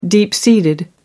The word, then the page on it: deep seated: American English